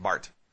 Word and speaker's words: Barth: So a guy named Karl Barth, I know it's T-H, but it's pronounced Barth, Karl Barth, came up with a philosophy, neo-orthodoxy.